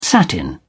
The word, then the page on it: satin: British English